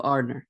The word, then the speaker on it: Arnor: And then the last one, Arnor. – Aramir? – No, Arnor. Arnor. – Give me the options again. The Shire. – Yeah. Rohan, Gondor, or Arnor? – I think I'd rather be from the Shire.